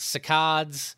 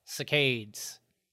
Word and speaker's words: saccades: because it's spelt S-A-C-C-A-D-E-S. So it could be spelt cicades. – S-A-C-C-A-D-E-S. – Cicades. – Cicades. It's not like a facade. – It's not like a facade. There's no accent.